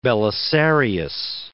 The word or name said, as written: Belisarius